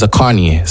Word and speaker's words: Arcani: My real name's Akani. So right. You spell that A-R-C-A-N-I.